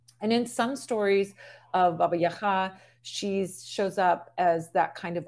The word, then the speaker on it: Baba Yaga: Baba Yaga is what we say in English, because that's really the Russian pronunciation, but that's the pronunciation that was sort of spread all over. So people will know her as Baba Yaga, or Baba Yaga, but in Ukrainian it's Baba Yaga. It's just a different sort of sound to that second syllable of Yaga or Yaga.